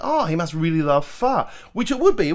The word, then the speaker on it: pho: P H O. Some say pho, but it's pronounced "fa," I believe.